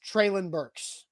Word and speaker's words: Treylon Burks: This is coming from the Tennessee Titans, Treylon Burks.